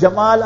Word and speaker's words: Gamal: If you read about Egypt and the history of Egypt, there was a ruler, the Egyptians, they pronounced the jim a bit different, you know. When they read Quran, it's excellent, but in their colloquial when they speak, his name was Jamal Abdul Nasser. But when they pronounce it and they write it, they write Gamal, you understand? Gamal Abdul Nasser.